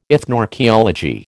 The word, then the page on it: ethnoarchaeology: American English